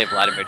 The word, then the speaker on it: Vladimir: You always have to say Vladimir just like that.